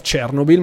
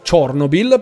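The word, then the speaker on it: Chornobyl: E eh, una cosa molto interessante, non si chiama più Heart of Chernobyl, ma si chiama Heart of Chernobyl perché è la pronuncia ucraina di Chernobyl e l'hanno cambiata proprio dopo il conflitto dopo il conflitto con eh, l'inizio del conflitto con la Russia.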